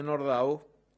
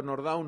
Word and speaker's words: Nordau: Y como hablaremos también de arte, resaltemos algo de la obra de Nordau, que es, digo Nordau, no Nordau, porque no era un nombre francés, viene más bien del polaco en la pronunciación, que sobresale por el rechazo que genera esta estupefacción.